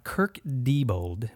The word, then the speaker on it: Kirk Diebold: We have Kirk Diebold.